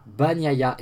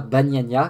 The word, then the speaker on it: Bagnaia: Voilà, Bagnaia et non pas Bagnagna, comme je l'avais appelé euh, la semaine dernière, donc Federico Bagnagna.